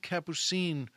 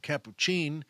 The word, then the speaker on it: Capucine: There isn't one moment, and Capuchin, I think they pronounce it Capucine, but I always grew up, my mother pronounced it Capuchin.